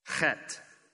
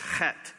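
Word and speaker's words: chet (חית): It is pronounced chet. The H there is pronounced with the guttural ch. Don't turn to your neighbor, neighbor and try it, or you'll spit all over his or her face. And the TH is actually a hard T, so it's chet.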